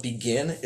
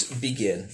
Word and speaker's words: BGEN: And we call it BEGIN. It's a play on words. Teachers hate it because it doesn't sound like it looks. It's phonetically impossible, but it's BEGIN.